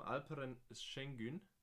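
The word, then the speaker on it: Alperen Şengün: Und da gibt's, und ich will ihn jetzt äh, nicht auf, ja, ich spreche ihn jetzt auf Deutsch aus. Ähm, Alperen Schengen. Habe ich es gut ausgesprochen? – War, so War, gar nicht schlecht. Alperen gell? Schengen, ja.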